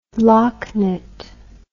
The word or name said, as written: locknit